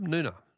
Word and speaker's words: Noongar: Noongar is pronounced with a silent G, is it? Oh, thanks, Barb. Noona. Okay.